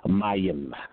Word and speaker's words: mayim: The Hebrew word is mayim. Mayim is spelled M-A-Y-I-M, of course, with the two vowel punctuation marks over the A and the I in English.